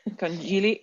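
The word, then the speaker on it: conchiglie: The part that GL, I think is li. – Oh, okay. Conchile? – Conchile.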